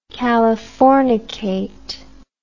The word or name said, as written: californication